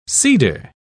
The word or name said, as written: cedar